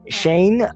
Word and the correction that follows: Cheyenne: Wyoming. Cheyenne, is it? Oh, God. – Cheyenne.